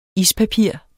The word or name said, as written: ispapir